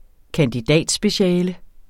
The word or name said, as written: kandidatspeciale